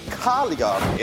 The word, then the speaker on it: Cagliari: I was told, Lindsay, it is Cagliari. It's a stress on the beginning of the, yes, in Italy.